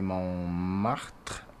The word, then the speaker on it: Montmartre: So check that out tonight at Café Montmartre. I can't not pronounce it that way. It's the correct way.